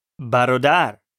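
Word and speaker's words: Barodar (برادر): Barodar.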